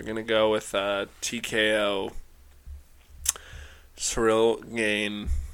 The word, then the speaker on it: Ciryl: I mean, couldn't pronounce that worse, but I think I'm gonna go with surreal Gane. Ciryl Gane. – Surreal, Gane. A surreal gain.